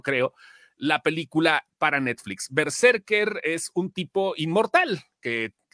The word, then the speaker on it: BRZRKR: Y si tú ves y vas a una tienda especial o buscas en línea, es B-R-Z-R-K-R, pero se pronuncia Berserker, nada más que sin vocales.